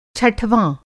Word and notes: छठवाँ: Hindi

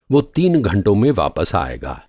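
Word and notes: वापस: Urdu واپس

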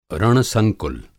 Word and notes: रण-संकुल: Hindi

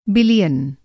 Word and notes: बिलियन: Hindi

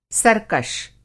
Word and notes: सरकश: Hindi

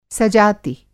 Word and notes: सजाति: Hindi

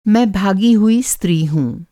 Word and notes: भागा: بھاگا

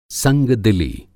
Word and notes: संगदिली: سنگدِلی

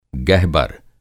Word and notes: गहबर: Hindi

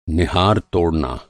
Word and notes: निहार: نِہار

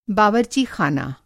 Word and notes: बावरचीख़ाना: Hindi

